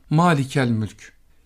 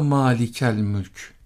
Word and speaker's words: Malikül Mülk: Bu Turan coğrafyasında, Arapçadan bu şekilde geçen coğrafyadan bize intikal ettiği için bundan nasibini Malikül Mülk ismi de almıştır. Biz kendimiz bunu telaffuz ederken halk arasında Malikel Mülk şeklinde telaffuz ederiz. Hatta ya Malikel Mülk dediğimizde çok daha doğru olmuş olur.